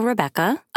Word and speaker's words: Rebekka: On social media, you can find me at Hello Rebecca. And my name is spelled R-E-B-E-K-K-A and no H.